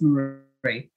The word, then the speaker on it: Maree: Is it Maree or Mari? – Well, it's up to the individual, but commonly known as Maree.